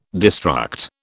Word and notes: destruct: British English

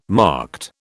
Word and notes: marked: British English

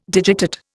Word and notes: digitate: Indian

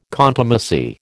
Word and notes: contumacy: American English

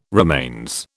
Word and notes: remains: British English

